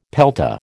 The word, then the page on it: pelta: American English